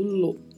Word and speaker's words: lo: E a pronúncia é lo mesmo, como se você tivesse um acento circunflexo em cima da letra O. É um som bem mais fechado, lo.